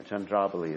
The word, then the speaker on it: Chandravali: Yeah, Chandravali is the right pronunciation.